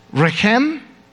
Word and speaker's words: rechem: In Hebrew, they're the exact same spelling, but they only vary in pronunciation. Rechem, womb, racham, compassion.